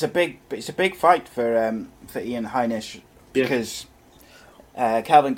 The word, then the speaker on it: Heinisch: Next up, middleweight bout between Kelvin Gastelum and Ian Heinz. Apologise if I pronounced his name wrong. You got there. – Heinisch.